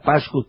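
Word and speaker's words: Pashkus: Y vos después le vas a explicar cómo se escribe, Ricky, R-I-C-K-Y, Pascus, P-A-S-H-K de kilo, U-S.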